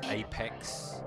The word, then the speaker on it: AP3X: It's probably meant to be pronounced Apex in a well wicked young styly like that.